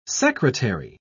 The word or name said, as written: secretary